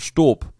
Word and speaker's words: Staub: Wir hatten ja hier, oder wir haben auch hier im Büro von der Firma, ich mache jetzt mal unbezahlte Werbung hier, das wird ausgesprochen Staub. Staub geschrieben.